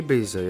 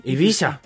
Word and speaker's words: Ibiza: bet nu, normāli ir Ibiza. Jā. – Jā, jo seriālā to izrunā pa spāņu modē, mazliet eh, nošļupstot to zēburtu. – Ibiza.